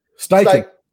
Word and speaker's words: Steichen: Steichen.